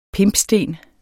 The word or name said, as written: pimpsten